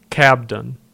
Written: Cobden

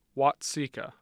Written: Watseka

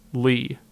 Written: Lee

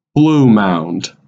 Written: Blue Mound

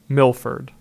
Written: Milford

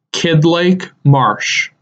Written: Kidd Lake Marsh

